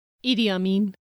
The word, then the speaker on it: Idi Amin: Which he does mention here when he's talking about the scavengers, but it's Idi Amin. – Idi Amin. I think that's the proper pronunciation.